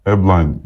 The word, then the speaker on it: Ablain: Leo, Zeze, Reinders, Duran, Ponomarenko, Diao, Ablain mi okunuyor? – Ablain diye okunuyor.